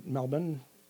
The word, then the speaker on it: Melbourne: I had one done in Australia, in Melbourne. I think that's how they pronounce it now. – I think you're right. – Really? – Wow. Yeah. – I always get it wrong. – They don't pronounce the hard R. – They don't pronounce the R, yeah.